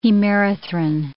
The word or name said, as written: hemerythrin